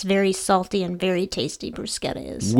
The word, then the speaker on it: bruschetta: Pork and bruschetta, although as we learned in pronunciations, it's actually bruschetta.